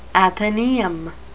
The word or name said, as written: athenaeum